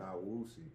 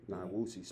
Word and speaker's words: Nyeusi: Nyeusi. – Nyeusi.